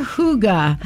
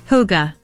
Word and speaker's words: hygge: Hooga. Hooga. Hooga. – But it's spelled Hooga. – H-Y-G-G-E. – Hooga.